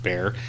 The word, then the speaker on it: Bexar: And San Antonio de Bear, I assume, is what we call San Antonio now in the county of Bear. And if you're not from Texas, Bear is B-E-X-A-R. – Right. – I don't know where that pronunciation comes from, because it doesn't sound Spanish. – Behar, I think, is how it would...